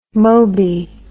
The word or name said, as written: moby